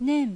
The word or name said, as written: Nen